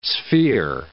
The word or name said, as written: sphere